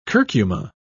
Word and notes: curcuma: British English

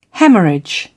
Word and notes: hemorrhage: British English

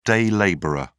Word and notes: day labourer: British English

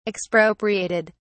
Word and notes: expropriated: British English

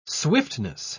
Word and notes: swiftness: British English

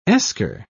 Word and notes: eskar: British English

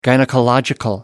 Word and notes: gynaecological: American English

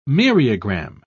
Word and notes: myriagram: British English

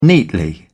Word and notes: neatly: British English

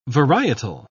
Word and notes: varietal: British English

